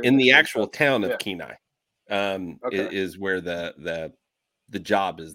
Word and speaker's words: Kenai: So we've been researching lately, how do you say it again? Kenai. – Yeah. Kenai.